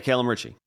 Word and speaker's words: Calum Ritchie: I guess it's pronounced Calum Ritchie. – Yeah, Calum Ritchie.